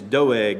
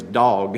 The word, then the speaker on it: Doeg: His name was Doeg. And he's a wicked man. You might even say his name was Dog, if you pronounce it another way.